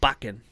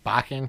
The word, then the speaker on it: Bakken: It's called Bakken. – Bakken. Can you spell that for our listeners, please? – B-A-K-K-E-N.